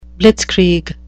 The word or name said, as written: blitzkrieg